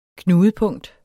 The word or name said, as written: knudepunkt